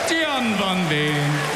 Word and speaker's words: Gian van Veen: Gian van Veen.